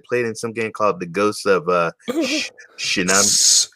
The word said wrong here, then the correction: Tsushima: Tsushima. It's pronounced Tsushima.